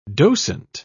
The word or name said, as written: docent